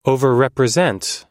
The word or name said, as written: overrepresent